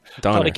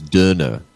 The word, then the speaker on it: Döner: Donner. – Yeah, D-O-N-E-R, but in Germany, there's the umlaut over the O, so there's Döner. Döner kebab.